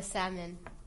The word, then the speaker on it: salmon: And I got the salmon. Or is it salmon? We were trying to debate about this before. So, you say how you pronounce it. – Salmon. – Salmon. – Says salmon with, pronouncing the L. And me and Karina think that's wrong.